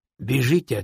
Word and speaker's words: бежите: — Бежите отсюда, пока я добрый! – — сказал я им, указывая направление стволом. Это не опечатка. В Одессе говорят «бежите» вместо «бегите». Произносится со звуком «и», а не «ы».